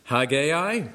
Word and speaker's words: Haggai: or maybe some of you pronounce it Haggai. I'm just doing the Northern Ireland pronunciation. We call it Haggai.